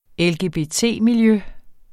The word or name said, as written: LGBT-miljø